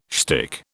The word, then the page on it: shtick: British English